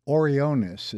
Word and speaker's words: Orionis: Yeah, that's Epsilon Orionis, and it, Orionis is actually how the Latin genitive should be pronounced, and it's called the Trapezium.